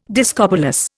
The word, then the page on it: discobolus: Indian